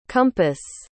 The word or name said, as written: compas